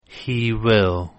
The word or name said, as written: he will